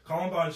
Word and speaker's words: Columbine: I think it does have correlation, because if you go back to the Columbine, that's how you say it, Columbine shooters, the music they were listening to and the books they read and stuff directly influenced that.